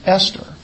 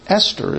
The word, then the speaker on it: Esther: And they gave this book the name Esther. In the Hebrew, it's not TH, It's just T.